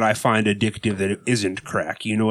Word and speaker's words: craic: First of all, being crack. C-R-A-I-C, pronounced crack.